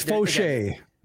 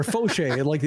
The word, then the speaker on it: Fauché: It's Fauché. Kelvin Fauché. – Fauché. All right. – Or Fauché. Like your Baseball Reference, the first syllable is capitalized. So Fauché.